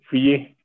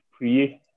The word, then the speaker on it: Priye: Is it Priye? Because that's a weird-ass name. – Yeah, my name is Priye. Priye. – Are you serious? – Yeah. – So this whole time, we're pronouncing it wrong.